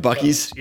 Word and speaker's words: Buc-ee's: Buc-ee's? – Yep. And I've traveled a bunch through Texas, so I've got experience outside of the Georgia. – Yes. So you are a walking, talking spokesman for Buc-ee's.